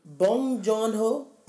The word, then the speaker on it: Bong Joon-ho: Bong Joon-ho.